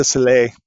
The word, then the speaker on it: Soleil: It's like they hired Cirque du Soleil, or Soleil, how do you say that? – Cirque du Soleil. – Soleil, there you go.